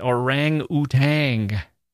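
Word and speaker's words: Orang-Outang: And he had a thing for orangutans. Orangutans. I pronounce it the way he spells it. Orang-utang, as he spells it.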